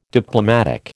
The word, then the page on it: Diplomatic: American English